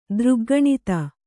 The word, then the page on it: ದೃಗ್ಗಣಿತ: dřggaṇita; Kannada